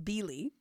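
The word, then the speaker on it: Bily: It's B-I-L-Y, but it's pronounced Billy.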